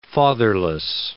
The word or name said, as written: fatherless